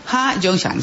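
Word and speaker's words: Ha-Joon Chang: Y también con ellos dos está el surcoreano Ha Jong-han, Shang es el apellido, Ha Jong-shang.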